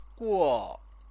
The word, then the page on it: guo: chinese